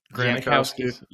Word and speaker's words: Janikowski: Do you think he would be like Robbie Gould, Adam Vinatieri, Zanikowski, or is it, how do you say his Janikowski, Sebastian Janikowski, and there's Granikowski as well.